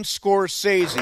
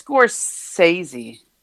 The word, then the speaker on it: Scorsese: 78 years old, the legendary Martin Scorsese, or Scorsese, depending on how you say it. Do you say Scorsese or Scorsese? – I think it's Scorsese.